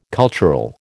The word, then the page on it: cultural: American English